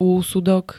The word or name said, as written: úsudok